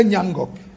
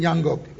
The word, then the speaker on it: Nyangok: I know my people here don't want to call that name. They don't want to bite their tongues. Say Nyangok. Say it again, Nyangok. It sounds difficult to pronounce, but it's a sweet name. Throughout my school years, all my teachers and lecturers had difficulties pronouncing this name.